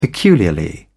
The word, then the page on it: peculiarly: British English